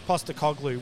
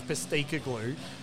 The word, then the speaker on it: Postecoglou: Um, Ange Postecoglou, which is hard at the best of times. – Yeah. – And when there's a program feeding it to you, she got Ange Postacoglu.